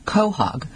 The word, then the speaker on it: quahog: These clams, also known as quahogs, pronounced quahog, are buried in the sand and mud along the Atlantic coast, often in estuaries and bays.